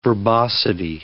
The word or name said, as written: verbosity